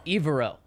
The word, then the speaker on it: Evero: And then they have their defensive coordinator, Evero. Am I saying it right? – Evero. – Okay. Yes. Ejiro Evero. – There you go.